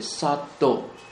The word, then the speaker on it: sattva: 那至于有情啊，有情啊，有情在梵文当中称之为 sattva， s a t t v a， v a 啊， s a t t v a， sattva 也可以念成 sattva，这个是梵文。如果是巴利文是念成 sattva， s a t t a SATTA 啊，这个是巴利文。